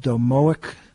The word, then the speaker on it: domoic: And the preliminary investigations are being done, and they're suspicious that it was due to a poisoning called domoic acid poisoning. I think that's how you pronounce it, D-O-M-O-I-C.